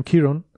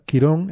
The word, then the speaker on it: Quirón: En inglés dicen Chiron. que yo miré en la Wikipedia y dicen que en español se llama Chirón, pero yo... – Quirón, ¿no? O la CH es la pronunciación. – Creo que debe ser Quirón,